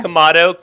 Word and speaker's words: Komodo: Did I say it right? Komodo? – Komodo. Komodo is a type of dragon.